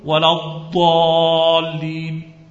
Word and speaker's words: ولا الضالين: وهذه ولا الضالين كأنها دالا، كأنها دال مفخمة، فخم الدال لما تقرأ ولا الضالين هكذا نقرأه، بينما الحجازيون والعراقيون لما ينطقون بالضاد في الفاتحة القراء قديما فيميلون إلى كونها ضاء مشالة، فإن سمعت العراقية أو الحجازية يقرأ فكأنك تظن أنه يقرأ الضاد ضاء،